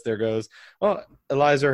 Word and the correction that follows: Elieser: Hern- I can't ever say his name. Hernandez. – Elieser. – Yeah, whatever. Elieser. Elieser. That's all I think was laser like the shark with laser hair.